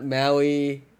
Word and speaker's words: Maui: Yeah, Maui.